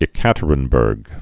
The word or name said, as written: Ekaterinburg